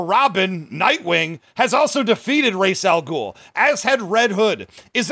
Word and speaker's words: Ra's al Ghul: First of all, it's Ra's al Ghul. We've already been over this.